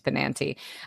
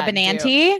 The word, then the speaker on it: Benanti: I always feel like I say Laura Benanti's name wrong. I think it's Benanti. Anyways,